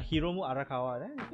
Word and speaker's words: Hiromu Arakawa: Like this one is Hirokamu Arakawa, I think. I forgot the name. Basically this is the artist behind Fullmetal Alchemist. Oh yeah, Hiromu Arakawa. I didn't say it wrong.